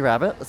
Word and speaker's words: Rabbit: Yeah, Chevy Rabbit. So it's spelled C-H-E-V-I and then Rabbit like R-A-B-B-I-T.